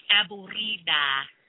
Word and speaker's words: aburrida: She said to roll your R's. Aburrida.